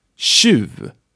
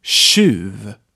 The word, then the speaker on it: tjuv: Tjuv, which means thief. Tjuv. And remember to say after me, okay? Tjuv. This word is spelled with T-J.